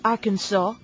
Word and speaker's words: Arkansas: que Arkansas se pronuncia Arkansas, O sea, no es Kansas y Arkansas, sino Kansas y Arkansas.